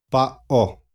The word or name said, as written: pao